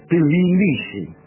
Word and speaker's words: Tbilisi: no sé cómo se pronunciará, me parece que Tbilisi. ¿Y por qué la primera I no la pronuncio? Tbilisi. Sería mucho mejor.